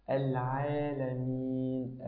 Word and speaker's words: العالمين: كلمة العين يا أخي الحبيب، فتح الله عليك، العين في كلمة العالمين لا تقول العالمين، فلتتفضل مشكورا.